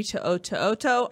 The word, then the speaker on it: To'oto'oto: PF on Facebook said, is USC going after the linebacker from Tennessee, Henry To'oto'oto? I always say that wrong. I apologize. Of the transfer portal. – I believe you said it correctly.